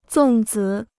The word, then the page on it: 粽子: zòng zi